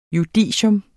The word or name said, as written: JUDICIETS